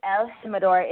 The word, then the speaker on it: El Jimador: Yeah, it has a J, but you know, the J in Espanol, you pronounce it like a H. You know, so it's El Jimador.